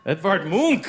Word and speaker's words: Edvard Munch: Edvard Munch.